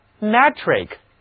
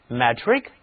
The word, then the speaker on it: metric: meter 米是一个公制单位，那么我们看 metric 这个词， metric m-e-t-r-i-c，其中含有的 i-c 是形容词后缀， m-e-t-r 则等同于 meter 米， metric 这是一个形容词，公制的，米制的，其中的字母 e 不再读单词 meter 中的短 e，而是读作扁口的 a。跟我来读 metric， metric，公制的。